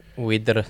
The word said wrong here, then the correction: Werder: Wider Bremen. Is that how you pronounce it? Wider. – Wider. – Werder. – Werder Bremen.